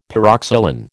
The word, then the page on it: pyroxylin: American English